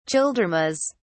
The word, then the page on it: childermas: British English